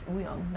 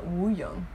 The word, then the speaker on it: Wooyoung: Like, if we're actually pronouncing it, then it's Ooyoung, not Wooyoung.